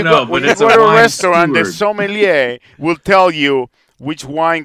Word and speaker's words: sommelier: So what is that word again? – A sommelier. – I can spell it. I can't say it. It's sommelier.